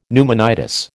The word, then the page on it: pneumonitis: American English